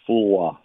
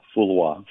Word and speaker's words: Fula: How do you pronounce your last name? I'm sorry. – You're very close. It's Fula. Fula. – Fula. Fula. So the L is not pronounced.